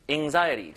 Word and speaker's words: anxiety: anxiety. 자, 이게 A 발음이지만 약간 으 느낌이 나고요. anxiety. anxiety. 약간 이런 느낌을 읽어주면 되거든요. 자, 중간에 강세 잘 주세요. anxiety. 끝에 T는 약간 리을 발음처럼 설탄음으로 발음됩니다. 들어볼게요.